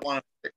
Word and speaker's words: Wannemacher: Yep, Wannemacher.